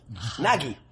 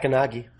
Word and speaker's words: Gnaghi: No, the G is. G-N-A-G-H-I. – Yeah, the first, first G. First G is silent. – Second G is there. – It's not Ganagi. It's just Nagi.